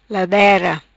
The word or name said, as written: Ladera